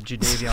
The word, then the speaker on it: Jadeveon: Jed Clowney? No, I'm done with Jed Clowney. – I don't even know where I was. I was so taken aback by your mispronunciation of Jadeveon Clowney.